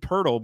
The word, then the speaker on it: Poeltl: It's Jakob Poeltl, but spelt like Jacob Poeltl.